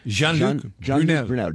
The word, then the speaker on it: Jean-Luc Brunel: J-E-A-N is Jean. – Jean. – L-U-C. Luc. – Luc. – B-R-U-N-E-L. – Brunel. – Brunel. – Jean-Luc Brunel.